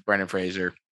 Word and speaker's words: Brendan Fraser: Look, we love Brendan Fraser. I think you said his name right. No one ever says his name right.